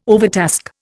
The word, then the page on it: overtask: Indian